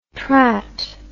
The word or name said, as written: trat